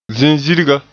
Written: zizirga

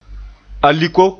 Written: aliko

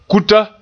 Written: kuta

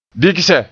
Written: bigse

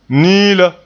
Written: niila

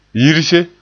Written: yirse

